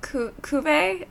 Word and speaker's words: Cuvée: Because there's like Brut, there's Cuvée.